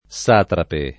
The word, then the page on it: satrapo: lingua italiana